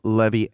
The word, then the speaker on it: Levi: The team met in the offices of attorney Enzo Levi. Levy? – I think Levy was probably the Italian pronunciation. – The team met in the offices of attorney Enzo Levy and drafted up a contract.